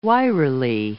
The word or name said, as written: wirily